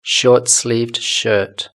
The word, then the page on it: shortsleeved shirt: British English